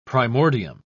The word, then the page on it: primordium: British English